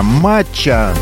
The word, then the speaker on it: матча: В списке холодный чай из гибискуса, матча, ударение на первый слог,